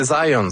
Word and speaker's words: Zajonc: Non, c'est Zajonc. – Ouais? OK. – J'ai-tu pas dit Zajonc? – Ben, j'avais dit plus Zajonc, mais... – J'ai prononcé un petit peu à l'anglais. J'ai prononcé mon polonais à l'anglais.